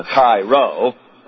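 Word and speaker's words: chi (χ): I was pronouncing it chi the other day. Is it chi? C-H-I? Some of you Greeks, anybody been in a fraternity? It's chi. C-H-I is pronounced chi.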